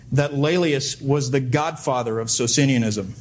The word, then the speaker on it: Laelius: I'll spell it for you. Laelius is L-A-E-L-I-U-S. Laelius.